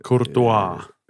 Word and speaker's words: Courtois: Courtois.